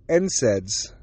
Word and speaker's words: NSAIDs: They don't want me taking any NSAIDs. That's how you pronounce it, by the way.